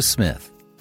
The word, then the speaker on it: Psmith: And now, Leave It to Smith. The P is Silent.